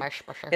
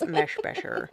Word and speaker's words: Meshbesher: Meshbesher and Adam T. Johnson. I know it sounded like I was not saying that right. – Meshbesher. – It's Meshbesher. April 29th,